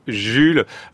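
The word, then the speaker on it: Jul: j'avais quelqu'un dans la compétition qui était le rappeur Jules, qui ça s'écrit pareil, ça se dit Jules comme ça.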